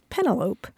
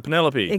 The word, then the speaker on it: Penelope: And I admitted that for a long time, I thought the woman's name was Penelope. – Oh, instead of Penelope.